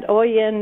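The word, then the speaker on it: Oian: So the hardest thing is my name is a different because it's B-O-N-I instead of right. – B-O-N-N-I-E. – Right. – And Oyen, nobody ever pronounces right or gets it right, but you did, so I'm impressed. – Yeah. – And it's O-I-A-N.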